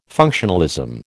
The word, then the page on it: functionalism: American English